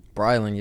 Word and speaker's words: Brylan: Brylan, yeah, you pronounced it right. Awesome. What's the last name? Donaldson, man. Brylan Donaldson.